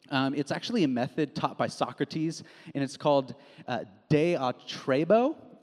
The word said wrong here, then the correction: diatribe: I don't know if I'm saying that right, but it's diatribe is how you say it, diatribe.